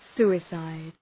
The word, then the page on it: suicide: ‘su:ı,saıd